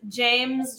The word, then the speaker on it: James: And James, just like the male name, James Music.